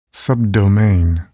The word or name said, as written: subdomain